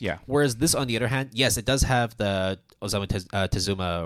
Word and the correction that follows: Tezuka Osamu: Did I pronounce that correctly? – Yeah. – Yeah, the Tezuka Osamu Award.